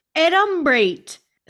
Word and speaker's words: adumbrate: adumbrate spelled A D U M B R A T E, pronounced ad um brate.